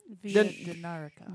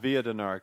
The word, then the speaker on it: Via Dinarica: But, you know, we just interviewed L-Train about what's the proper pronunciation? – Dinarica. – The Dinarica. – Via Dinarica.